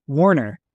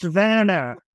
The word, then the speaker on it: Werner: Warner. And and Warner says, quote, with angry eyes, it's pronounced Werner.